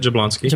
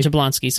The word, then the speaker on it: Jablonski: Who can probably pronounce his name better than I can. – No, that's it, Jablonski. – Jablonski.